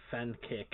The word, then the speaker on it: Fensick: Um, the C is pronounced as a K. Um, oh, it, Jake Fenkick.